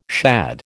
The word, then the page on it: shad: American English